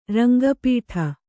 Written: ರಂಗಪೀಠ

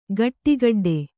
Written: ಖಡಖಡಾಟ

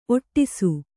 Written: ಒಟ್ಟೈಸು

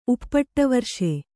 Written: ಉಪ್ಪಟ್ಟವರ್ಷೆ

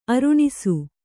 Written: ಅರುಣಿಸು

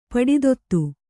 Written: ಪಡಿತೊತ್ತು

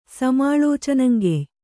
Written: ಸಮಾಳೋಚನಂಗೆಯ್

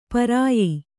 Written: ಪರ್ಯ

